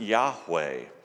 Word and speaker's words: Yahweh: And in the Hebrew language, the word Lord is pronounced Yahweh.